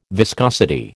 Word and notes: viscosity: American English

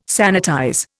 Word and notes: sanitize: Indian

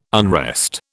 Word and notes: unrest: British English

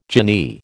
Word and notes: jinnee: American English

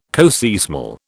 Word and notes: coseismal: British English

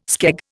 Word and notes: skeg: Indian

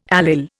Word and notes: allyl: Indian

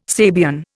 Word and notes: Sabian: Indian

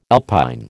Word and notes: alpine: American English

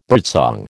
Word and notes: birdsong: American English

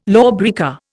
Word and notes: lawbreaker: Indian

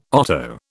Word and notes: otto: British English

